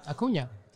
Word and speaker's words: Acuña: I don't know if it's a hot take or thought process with the Acuña stuff. And please, do I say that right? Because everyone always tells me. You did very well. – I did? – Mm-hmm. Ha! Acuña.